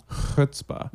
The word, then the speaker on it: chutzpah: Chutzpah.